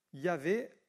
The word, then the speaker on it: Yahvé: Dans l'Ancien Testament, son nom c'est Yahvé, on dit, c'est le tétragramme divin, c'est YHWH, on le prononce Yahvé,